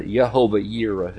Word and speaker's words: Jehovah Jireh: So Abraham called that place Jehovah Jireh, or Jehovah Jireh, as some pronounce it, but the Lord will provide.